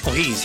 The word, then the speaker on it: O'Higgins: Su compromiso con O'Higgins, uno de los partidos más importantes. ¿Se lo dije bien? Sí, O'Higgins, ¿cierto?